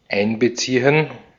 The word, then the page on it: einbeziehen: [ˈʔaɪnbəˌtsiːən]